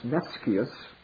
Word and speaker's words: nescius: The word nice comes from the Latin word nescius, or actually it should be pronounced nescius, which means ignorant.